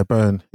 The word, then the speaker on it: Byrne: And Rose Byrne? – That's the one. Yeah, Byrne. Yeah. – Oh, Byrne, sorry, yeah. I don't know how to pronounce that surname.